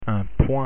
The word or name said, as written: point